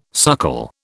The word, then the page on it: suckle: British English